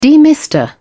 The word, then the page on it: demister: British English